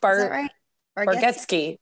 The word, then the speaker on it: Bargatze: It's not Getsky. Yeah. It's not Ski. – No. Rob, if Rob was here, he would say it correctly. Cause he, always the Tennessee kid. – Yes. He always like, he's like, no, he corrects me all the time on it. He's like, no, there's no ski in it.